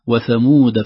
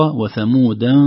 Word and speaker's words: وثمود: التنبيه الأخير، وثمود فما أبقى. لو المستمعين الكرام ينظروا في المصحف الآن سيجدون أن كلمة ثمود بعدها ألف، في الواقع هذه الألف لا تنطق، مرسومة فقط، لأنها في بعض القراءات الأخرى وثمودا فما، لكن بقي رسمها كذلك عند حفص.